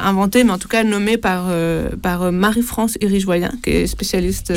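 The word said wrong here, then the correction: Hirigoyen: Irigoyenne. Ça se prononce Irigoyenne, je crois.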